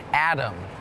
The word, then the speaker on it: atom: atom is tricky because it sounds like there's a d in it atom but it's actually a t o m but it's still pronounced atom